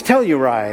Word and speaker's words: Telluride: In fact, for the first week, I couldn't even say it right. It was Telluride. And he's like, no, it's Telluride.